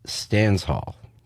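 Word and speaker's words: Stanshall: Viv Stansall, I'm going to say this wrong every time. It's Stansall.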